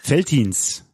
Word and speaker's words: Veltins: Oder wie spricht man das Veltins aus? – Veltins.